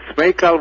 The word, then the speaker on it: Schmeichel: And, you know, one was yourself, and one was Hort, and the other was Schmeichel. Am I saying that correctly? – Yes, Schmeichel was he's three years younger than me.